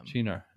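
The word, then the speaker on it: Cynar: Is that how you pronounce that? – Chinar? – That. – Yeah. – Chinar. Yeah. Oh.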